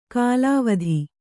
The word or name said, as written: ಕಲಾವತಿ